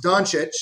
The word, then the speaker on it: Doncic: It's pronounced Doncic, so you're right on.